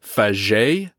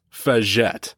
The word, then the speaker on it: Faget: Suffice to say, the proper pronunciation is Faget, not Faget.